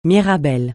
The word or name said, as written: mirabelle